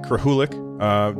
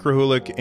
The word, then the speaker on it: Krahulik: So hey everybody, I'm here with Mike Krahulik. Did I say that right, by the way? – You did. Yeah, you nailed it, actually. – I've been practicing. I'm here with Mike Krahulik, and he is the artist and co-founder of Penny Arcade.